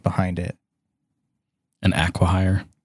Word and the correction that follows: acquihire: An acquire. An acquihire. Is that how you say it? Yeah.